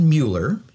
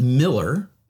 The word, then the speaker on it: Mueller: It's spelled Mueller, yes. And half Texans being what we are here. I'm not native Texan, but half the population pronounces it Miller. and half of them Mueller.